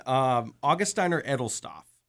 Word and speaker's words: Augustiner Edelstoff: How do you pronounce this again? Because my German reading is not really that great. – Neither is mine. Augustiner Edelstoff.